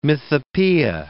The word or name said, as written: mythopoeia